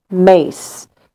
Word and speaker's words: Myss: And it's by Carolyn Mace. Her last name is spelled M-Y-S-S.